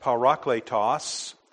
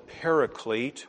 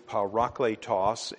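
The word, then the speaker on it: parakletos (παράκλητος): It's pronounced parakletos. Sometimes people call it the paraclete, which is okay, but the actual rendering is parakletos, and it's a compound word, kletos, from kaleo, has the idea of called, and para, a preposition, has the idea of alongside.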